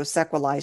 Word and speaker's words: sequelae: I mean, it's Latin, so sequelae should be the proper pronunciation from the Latin girl here.